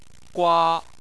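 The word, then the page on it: gua1: tone 1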